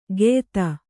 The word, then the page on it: ಗೆಯ್ತ: geyta